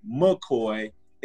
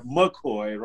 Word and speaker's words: McCoy: You can definitely follow me at Millionaire McCoy. It's spelled like Millionaire McCoy, right? Hope you know how to spell Millionaire. M-C-C-O-Y.